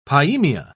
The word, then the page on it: pyaemia: British English